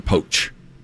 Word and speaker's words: Pochettino: This Pochettino, am I saying that right? Mauricio? – I don't know. – Is it Pochettino or Pochettino? – I've heard Poch.